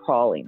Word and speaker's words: praline: it kind of inspired the whole praline run, praline, I should say, I'm pronouncing it like a Yankee, the praline run in Fatal Cajun Festival.